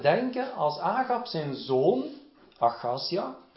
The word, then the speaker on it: Achazia: We see that Ahaziah, is that correct? Ahaziah, is that the English pronunciation? – Achazia, okay.